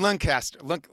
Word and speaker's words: Lancaster: How do you say it right? – Harrisburg and Lancaster. – That was the one everyone was giving me shit about. Because I think I was like Lancaster or something.